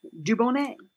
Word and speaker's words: Dubonnet: Is it spelled the same? I think it is Dubonnet. – Yep. – Yeah. – So there you have it. Dubonnet.